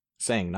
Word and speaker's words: Sang: Sang, not song. – Oh, because in the English version, they pronounce it song. – It's got an E in it.